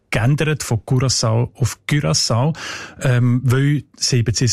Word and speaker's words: Curaçao: Was sagt denn die Datenbank zu den Menschen von Curaçao? – Curaçao heisst die Insel, habe ich gelernt. – Sorry? – Mit Ü, genau.